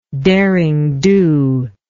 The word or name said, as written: derring do